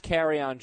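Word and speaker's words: Kerryon: I think it's yeah, yeah. There's an on at the end of his name, so carry on or carrion.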